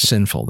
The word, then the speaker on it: Cinnful: But, now, Sinful is spelled C-I-N-N-F-U-L, right? – That's correct. Sinful, that's correct.